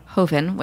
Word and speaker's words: Joven: So let's talk about the ways in which chefs, for example, have helped on this journey, because you have these beautiful, the Hoven, which... It's spelled like Joven, all of you people out there, because which was how I was gonna pronounce it until I learned better.